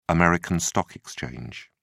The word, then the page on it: american stock exchange: British English